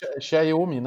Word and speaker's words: Xiaomi: uma Xiaomi, né? É, é assim que pronuncia? – É, é.